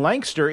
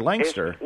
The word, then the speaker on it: Lancaster: Well, you know, you can you can phonetically spell it L-A-N-K-S-T-R, Lancaster. – Lancaster. – Yeah, put a little apostrophe in there between the K and the S, Lancaster.